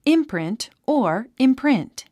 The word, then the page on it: imprint: ímprint; インプリント